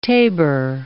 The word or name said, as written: tabor